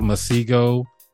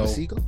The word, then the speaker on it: Masego: Masego, that's how I say his name, right? – That's how I said it. I think that's how I just said it. So, Masego, he's good to me.